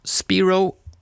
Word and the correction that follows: Spiro: nej förlåt, Spyro ska det uttalas.